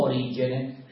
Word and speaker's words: Origene: Cominciamo con il più antico, Origene, o Origene, secondo che si pronuncia alla latina o alla greca.